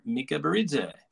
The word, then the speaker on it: Mikaberidze: Alexander Mikaberidze.